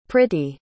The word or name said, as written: priti